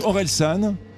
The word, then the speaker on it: Orelsan: I think I'm pronouncing it wrong. I think it's Horizon. – Ah, Orelsan.